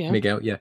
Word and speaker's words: Miguel: So Miguel, is that pronunciation correct? – Miguel.